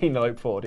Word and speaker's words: Penelope: Penelope Ford, yes.